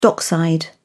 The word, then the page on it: dockside: British English